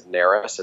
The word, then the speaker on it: Narus: It's R-Y-A-N Ryan. And my last name is Narus. It's spelled N-A-R-U-S, N like Nancy.